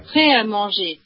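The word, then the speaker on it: Prêt à Manger: This is how you're supposed to pronounce it. – Okay. – Pret-a-Manger.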